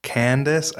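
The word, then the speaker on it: Candess: And real quick, also, I want to thank Candace. I think it's pronounced Candace, but it's C-A-N-D-E-S-S.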